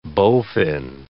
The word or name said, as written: bowfin